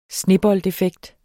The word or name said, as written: sneboldeffekt